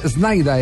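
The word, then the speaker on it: Sneijder: Oiga, eh, eh, es que hay una historia con, con, eh, con Snyder, eh, que Snyder es como se pronuncia en, holandés. Wesley Snyder. Snyder, Snyder. Se escribe Snyder, pero ese es Snyder.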